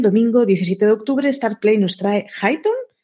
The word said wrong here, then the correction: Hightown: Hightown, perdona que lo he pronunciado mal, y Amazon Prime Video trae Motherland Fort Salem, la, segunda temporada.